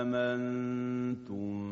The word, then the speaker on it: آمنتم: در سوره های اعراف، طه و شعرا، از جمله آیه 123 سوره اعراف، که اینجا هم باید دقت کنیم که ابدال صورت نمیگیره. با هم گوش میکنیم به تلاوت آیه 123 از سوره مبارکه اعراف. عنایت کنید به تلفظ کلمه آمنتم.